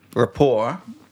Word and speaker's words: rapport: And so, rapport... How is it spelled, Chris? – RAPPORT. – But the T is not pronounced because it's French.